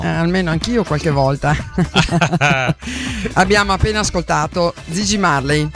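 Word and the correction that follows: Ziggy: No, attenzione, no, Gigi. – Zigi. – Zigi, Zigi. – Perché io ho la pronuncia della Z che è un po' particolare.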